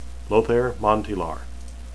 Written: lothair

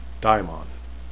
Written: dhaimon

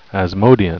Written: asmodean

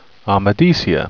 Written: amadicia

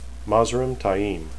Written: Mazrim

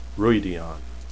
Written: Rhuidean

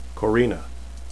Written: corenne

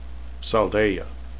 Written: saldaea

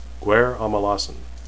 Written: guaire

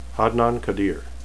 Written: hadnan

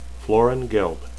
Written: floran

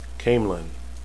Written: caemlyn

